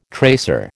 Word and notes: tracer: American English

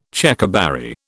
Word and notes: Checkerberry: British English

